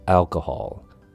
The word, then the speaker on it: alcohol: Next up, we have alcohol. Three syllables. Al sounds like the name Al, like Al Bundy from the American TV show Married with Children. If you got that reference, then I'm impressed. K has a short U sound, K, Hall sounds like the hall you have in a school or where you hold a banquet or a big event. Hall. Alcohol.